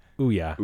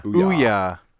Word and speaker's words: Ouya: Ouya. – Ouya.